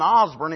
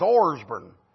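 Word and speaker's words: Orsbourn: It wasn't Osburn, it's Orsburn. That's the way it's spelled. O-R-S-B-O-U-R-N. I thought it was Osburn, but if you said it wrong, she said it's Orsburn.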